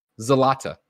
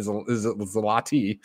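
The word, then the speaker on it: złoty: Big Bad Beluga says Polish bucks is pronounced Zlata in Polish, a lot less fake sounding than Zlati. Yeah, Zlati.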